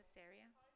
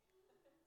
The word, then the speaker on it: Caesarea: Caesarea? How do you say that? – I say Caesarea. – Caesarea.